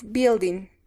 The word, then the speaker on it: building: The word is building. Building. – Good. Building. La U es muda. Building. No se pronuncia la U.